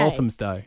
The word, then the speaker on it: Walthamstow: Walthamstow.